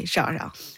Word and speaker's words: Xiao Xiao: So that's how I say Xiao Xiao.